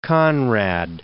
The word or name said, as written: Conrad